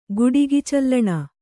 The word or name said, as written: ಗುಡಿಗಿಚಲ್ಲಣ